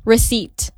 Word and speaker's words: receipt: So like the correct way for you to actually pronounce it is receipt. – Oh. – Receipt. Langsung jump into T-nya.